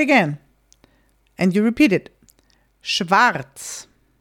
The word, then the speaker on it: schwarz: Also, sometimes Austrians and German, like German-speaking people, we will, in this case, just not say the R. There is an R in this letter. You spell it, the whole word schwarz, black, is spelled S-C-H. W-A-R-Z. So there's an R at the very end or the second to last word. And very often we'll just leave it out.